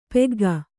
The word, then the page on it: ಪಕ್ಕಾಗು: Kannada